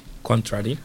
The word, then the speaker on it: contrary: Contrary. – Contrary, exactly, very good. Moviendo el acento, la entonación, una sílaba o dos hacia atrás. Entonces, contrario, contrary, very good.